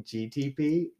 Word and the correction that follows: GPT: You say it wrong, yeah. – But it's GPT. I think it's because I played GTA.